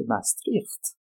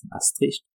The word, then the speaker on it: Maastricht: Ensuite, cette année 92, c'est le référendum sur le traité de Maastricht, prononciation néerlandaise, Maastricht, prononciation française, avec un petit, comme on dit, 51% de oui.